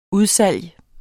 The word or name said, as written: udsalg